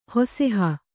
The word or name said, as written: ಹುಸಿಹೂ